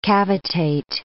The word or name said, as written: cavitate